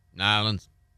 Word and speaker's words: New Orleans: I think you're mispronouncing the name of that city. – Really? – New Orleans. – That's accurate. That is accurate.